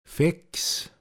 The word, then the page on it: a guade Duul: Pinzgauer Mundart